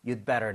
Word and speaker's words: you'd better: 여기서도 you had better 보다는 you'd better not. – You'd better not. – Alright? You, do인데 do 사운드가 거의 안 나요. You'd better, You'd better.